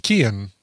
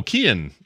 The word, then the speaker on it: Cian: By the way, C-I-A-N, we're getting corrections, is pronounced Kian. – Oh, Kian. Okay. – Kian. The guy from Peaky Blinders is obviously he's not Kian, but it's also like a hard C as opposed to a soft C.